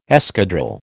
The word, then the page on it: escadrille: American English